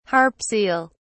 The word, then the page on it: harp seal: British English